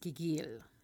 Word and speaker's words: gigil: I think it's pronounced gigil. I think is the Filipino pronunciation for it.